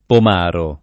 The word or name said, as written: pomaro